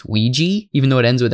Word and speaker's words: Ouija: So the hilarious thing about, I don't know why the common pronunciation of this is now Ouija, because Ouija is a made-up word. It literally isn't a real word.